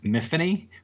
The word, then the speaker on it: Myfanwy: And I like I said, I'm I'm obviously not English, um, but the is there ever a cool is a cool a name is Myfanwy rhymes with Tiffany?